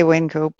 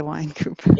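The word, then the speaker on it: Wynkoop: I say Wincoop. How do you say that? Wincoop or Winecoop? – I say Wincoop, but yeah, I say Wincoop. – Some people say Winecoop.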